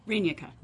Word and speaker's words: Rynicka: Rynicka. – There you go. – It's the emphasis on the wrong syllable.